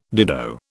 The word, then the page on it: dido: British English